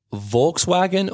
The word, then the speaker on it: Volkswagen: Yeah, you would pronounce the V as an F, Volkswagen, and the W as a V to overcomplicate it for you.